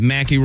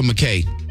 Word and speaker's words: Mackay: Westgate City Boulevard at Mackey Road. Is that Mackey or McKay? – How you spell it? – M-A-C-K-A-Y. – I would go Mack K.